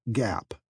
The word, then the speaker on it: GAAP: In the United States, those rules are called Generally Accepted Accounting Principles, or GAAP, pronounced GAP.